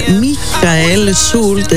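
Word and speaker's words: Michael Schulte: Μίχαελ Σούλτ, έτσι για να πούμε και σωστά με προφορά το όνομά του, από τη Γερμανία.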